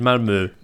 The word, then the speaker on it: Malmö: They looked in the driving seat after 10 minutes when they were 2-0 up, and they let Malmer, I believe they're pronounced, I was told. – Well, we got told the other night that we were pronouncing it correctly when we said Malmö. – Malmö. – Malmö. Malmö. Right. Yep. Well, I'm going to call them Malmö.